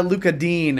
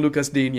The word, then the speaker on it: Digne: I'm going to get Luca Dean a French pronunciation guide for the pundits because, damn it, they're still saying it wrong in America. Everybody is still saying Lucas Digne.